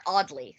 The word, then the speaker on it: Audley: Kentucker Oddler. – That's not a real name. – Kentucker Oddly. Sorry. – Oh, Kentucker Oddly. – It's still not great. It's spelled, the last name is spelled Audley. Oddly.